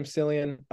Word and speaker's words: Cillian: How do you say his name? Cillian? – Cillian's right.